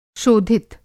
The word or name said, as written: शोधित